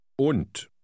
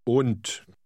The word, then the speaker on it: Und: Und. – Notice that in word 16, the D at the end is pronounced like T. Read 16 again. – Und.